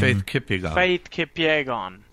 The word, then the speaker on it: Faith Kipyegon: Faith Kipyegon.